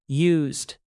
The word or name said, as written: used